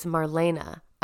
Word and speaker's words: Marlene: He talks about Betty Cottreau, Marlene Dietrich, Catherine. Oh, actually, it's Marlena. I was listening to a podcast and that's how they were pronouncing it, Marlene.